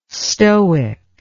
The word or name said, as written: stoic